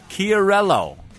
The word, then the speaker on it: Chiarello: I'm sorry, it's not Chi, it's Key. – So you fuck it up. – Chiarello, said smoothly. Who's that smooth enough, John? Chiarello. Chi is pronounced Key in most Italian names and words.